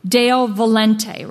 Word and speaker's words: Deo volente: There was a Latin word that came into use in the 1700s, Deo Volte. It's spelled D-E-O, new word, V-O-L-E-N-T-E. Deo Volente, rather.